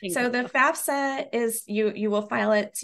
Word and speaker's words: FAFSA: Now, with circling back to FAFSA, is that how you say it, FAFSA? – Yep.